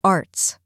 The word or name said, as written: arts